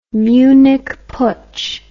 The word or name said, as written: Munich Putsch